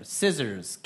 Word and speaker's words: scissors: Crayon, stapler, scissors, calculator. – Scissors. – And the silent letter? – C.